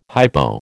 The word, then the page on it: hypo: American English